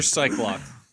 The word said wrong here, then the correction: Psylocke: How do you pronounce that? – Psylocke.